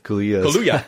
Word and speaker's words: Kaluuya: I can never say his name. – Kaluuya. – Oh, thank you.